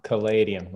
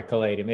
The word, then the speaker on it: Caladium: .com Caladium, like the plant. – How do you spell it? – Okay. – C-L-L. Like Palladium with a C. – C-L-L-A-D-I-U-M? – C-A-L. No, one L. Sorry, Caladium.